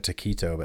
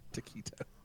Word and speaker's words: taquito: I think I say it with a softer T, like taquito.